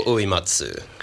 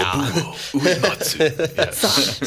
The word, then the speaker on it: Nobuo Uematsu: Nobuo Uematsu. – Great. Yeah. Nobuo Uematsu.